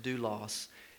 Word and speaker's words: doulos: The word that's translated servant in the ESV is a word in the Greek that's pronounced doulos, and it actually means slave.